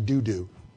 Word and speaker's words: Dodo: I'm the son of Dodo. Well, we're fighting. What did you say about my dad? And I had somebody tell me once, you're pronouncing that wrong. It's actually doo-doo.